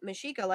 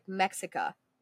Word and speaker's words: Mexica: Again, Mexica, like I said, is spelled like Mexica. M-E-X.